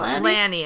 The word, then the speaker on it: Lani: And also, she pronounced her name Lanny? – No, Lanny. It's Annie Lanny.